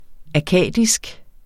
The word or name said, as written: akkadisk